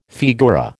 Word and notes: figura: American English

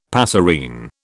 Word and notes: passerine: British English

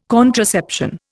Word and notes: contraception: Indian

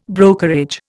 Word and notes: brokerage: Indian

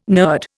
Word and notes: nurd: Indian

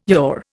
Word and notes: Yahweh: Indian

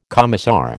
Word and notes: commissar: American English

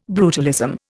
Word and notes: brutalism: Indian

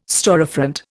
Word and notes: storefront: Indian

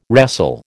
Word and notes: wrestle: American English

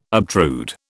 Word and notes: obtrude: British English

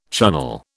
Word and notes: Chunnel: British English

